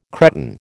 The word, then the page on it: Cretan: American English